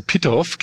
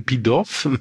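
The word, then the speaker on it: Pitof: Pitoff, glaube ich, ne? – Pitoff, ja, muss französisch ausgesprochen werden, Pitoff oder so, ist ja Franzose, der hat ja auch im Laufe seiner Karriere nur diesen Film gemacht und dann, glaube ich, noch irgendeinen französischen.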